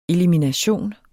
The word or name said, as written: elimination